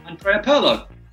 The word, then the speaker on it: Andrea Pirlo: Andrea Pirlo.